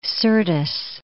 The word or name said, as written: Syrtis Major